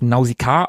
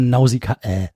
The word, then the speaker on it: Nausicaä: Übrigens, ich würde das Nausicaä aussprechen, weil im Film wird sie ja auch so genannt und der Untertitel ist Im Tal der Winde. – Na gut. – Ich sag mal Nausicaä.